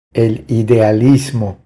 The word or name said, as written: El idealismo